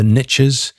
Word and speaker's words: niche: The Americans have an expression, the niches or the riches are in the niches. In other words, the word niche, French word, is pronounced as niche.